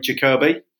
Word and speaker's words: Jacoby: So he said, "Sporting Rifle's just been launched, edited by Charlie Jacoby, emphasis on the O."